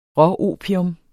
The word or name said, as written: råopium